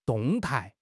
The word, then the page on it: 动态: HSK6